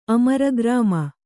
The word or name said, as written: ಅಮರಗ್ರಾಮ